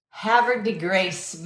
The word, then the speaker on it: Havre de Grace: Oh, Havre de Grace, Maryland. That's awesome. Thanks for the pronunciation help.